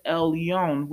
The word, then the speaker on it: Elyon: Well, it could mean a couple of things because it's used different ways um, it's uh, pronounced El Yom, and it means to go up or ascend. And so if you see it, it appears just El Yon, which appears in the scripture 34 times just by itself, mostly in Psalms, and then finally in Deuteronomy.